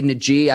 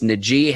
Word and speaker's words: Najee: And Ike, I say Najee, I've been mispronouncing his name all along. Our viewers and listeners and commenters, let me know. It is Najee Harris, the running back out of Alabama.